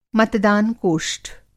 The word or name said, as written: मतदान-कोष्ठ